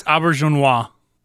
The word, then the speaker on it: Auberjonois: I think it's Auberjonois. – That would well done. I think you're probably right.